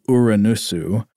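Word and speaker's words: Uranusu (ウラヌス): In Japanese, Uranus or Uranus is," and then he uses some Japanese characters, "which you would write as Uranusu, which is pronounced with all short vowels. So I guess that'd be Uranusu. – Uranusu.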